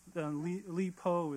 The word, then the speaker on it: Li Bo: The second poem is called Dreaming of Li Bo, who Jessica already introduced to you, oddly enough. Li Po is how most people pronounce it in English.